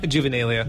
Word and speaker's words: Juvenalia: And the singer, Graham, labeled it Juvenella. How do you say that? – Juvenalia. – Juvenalia. What did I say? – Juvenella.